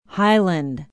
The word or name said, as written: highland